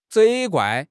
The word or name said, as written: 责怪